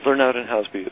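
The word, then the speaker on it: Lernout & Hauspie: and that's recently happened to Kurzweil Educational Systems, of course, with Lernout and Housby. Mm-hmm. I presume that's the correct pronunciation, because my speech synthesizer says it completely differently. – well, Lernout and Housby is correct.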